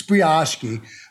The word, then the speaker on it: brioche: And Agita, have some brioche. It's brioche.